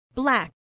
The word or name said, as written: black